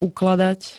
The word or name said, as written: ukladať